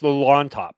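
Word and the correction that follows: Llontop: No, I was just correcting you to tell you it's Yontop because double L's are like right. A Y in Spanish.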